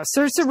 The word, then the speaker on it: Saoirse: And it stars Sorceret. How do you say her name? – I don't know. She's got to get a new Saoirse Ronan, who's wonderful, just great acting all the way through.